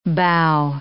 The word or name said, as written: bough